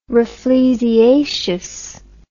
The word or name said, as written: rafflesiaceous